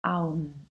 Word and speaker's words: Om: Und wenn du diese Silbe auseinander nimmst, erhältst du eigentlich das A, das U und das M, also Aum.